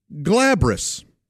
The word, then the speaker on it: glabrous: It's glabrous, and it's spelled G-L-A-B-R-O-U-S. Glabrous, and I believe it's glabrous, actually.